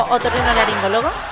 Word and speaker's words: otorrinolaringólogo: ¿Es como otorrinolaringólogo? – Sí, fonoaudiólogo. ¿Otorrinonaringólogo? – No, otorrino. – ¿Cómo? Laringólogo. – ¿Naringólogo? – Laringólogo. – Laringólogo.